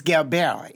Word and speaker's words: Gilbert: It's Gilbert. It's French.